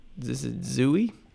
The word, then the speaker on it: Zooey: Zooey Deschanel, or is it Zooey? – Zooey. – It's spelled Zooey, though. – The second O is silent. – Okay. That's a weird word. Z-O-O-E.